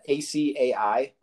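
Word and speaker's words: açaí: I've got this Resolution Blueberry IKEA, IKEA, IKEA Golden Ale. How do you say that? A C A I. – Oh, acai.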